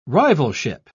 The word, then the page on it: rivalship: British English